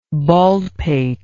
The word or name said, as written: baldpated